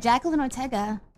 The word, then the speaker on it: Jacqueline Ortega: Yeah, you did. Jacqueline Ortega. That is correct.